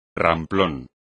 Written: ramplón

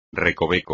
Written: recoveco

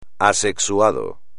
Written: asexuado

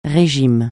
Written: régime

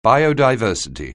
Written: biodiversity

